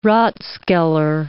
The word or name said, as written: rathskeller